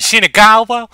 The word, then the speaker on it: Shinagawa: I don't know why I put on a Texan accent when I asked that, but I just loved your pronunciation, Shinagawa.